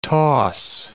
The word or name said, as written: toss